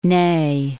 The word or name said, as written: Ney